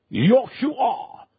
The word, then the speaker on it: Yahshua: For in that name, the word salvation is Yahshua. Yahshua. Yahshua. That is how you pronounce the word salvation.